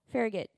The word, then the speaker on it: Farragut: Is it Farragut? Farragut? Farragut. All right,